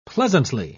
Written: pleasantly

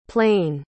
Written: plein

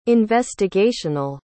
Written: investigational

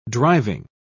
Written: driving

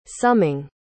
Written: summing

